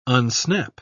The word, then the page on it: unsnap: British English